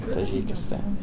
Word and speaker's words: Tajikistan: So is there anything then when you go back to home, trying not to say Tajikistan because I don't know if I'm pronouncing it right. Am I pronouncing it right? – Tajikistan.